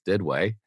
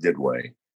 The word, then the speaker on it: Didway: In Southern Ohio lingo, it's pronounced Didway. In French, it's pronounced Didway. And our family was part of the French 500 that settled this area.